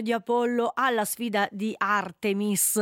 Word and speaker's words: Artemis: Allora, devo prendere la pronuncia, visto che è un programma di origine principalmente statunitense al quale partecipa l'Europa e anche la Svizzera, Artemis. – Artemis. – All'inglese, Artemis.